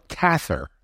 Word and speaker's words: Cather: Now, I always called it Willa Cather, and I found out during this podcast you say her name like gather, Cather.